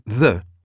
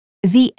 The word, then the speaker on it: the: We usually pronounce the with a weak form. But when it comes before a vowel, A, E, I, O, U, we pronounce it with a long form.